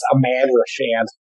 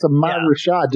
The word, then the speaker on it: Ahmad Rashad: I think it's pronounced Ahmad Rashad. – Ahmad Rashad. – It's Ahmad Rashad.